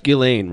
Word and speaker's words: Ghislaine: It's pronounced Ghislaine, right? – Yeah.